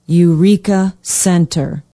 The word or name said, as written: eureka center